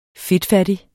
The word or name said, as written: FEDTFATTIGT